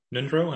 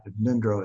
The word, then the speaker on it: Ngöndro: Today, the subject is Ngöndro. Am I pronouncing that roughly? – Yes. Yes. Yeah. It's a little tricky sometimes because it has a silent G and an NG. And so that kind of throws people off a little bit. But yeah, Ngöndro is right.